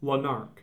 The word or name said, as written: Lanark